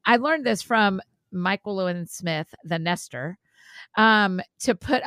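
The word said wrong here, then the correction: Myquillyn: Michael Lynn, it rhymes with Jacqueline.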